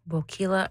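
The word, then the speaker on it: Boquila: Bokila rhymes with tequila.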